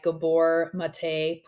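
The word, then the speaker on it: Gabor Maté: The Myth of Normal that just came out by Gabor Mate. Please correct me on the pronunciation. – Yep, you're correct.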